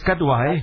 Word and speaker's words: Kadwa: Is it K-A-R-Y-A? – K-A-D-W. – Oh, okay. It's not K-A-R-Y-A. – No. – Okay. It's not Muhammad Kar something else. No? It's Kadwa, eh? – G.